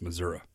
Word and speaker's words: Missouri: and I said, not Missouri. He said, oh no, it's pronounced Missouri.